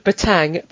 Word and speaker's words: batang: And at one point, Daredevil bounces off a car and the sound it makes is batang. And then Stanley does a little note that says, special note for those who may read this story aloud. In the sound batang, the second N is silent.